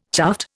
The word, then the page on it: chuffed: Indian